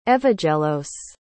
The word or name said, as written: evagelos